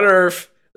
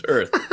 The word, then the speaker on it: Earth: It's not Earth. There's no F. uh, no PH. There's no F. It's TH. It's Earth.